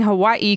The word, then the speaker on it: Hawaii: You are saying Hawaii correctly. The Hawaiian language pronounces every letter.